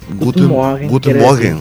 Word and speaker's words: Guten Morgen: Guten, Morgen.